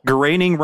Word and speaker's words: Groening: because the phrase Groening rhymes with complaining.